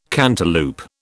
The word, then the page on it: cantaloup: British English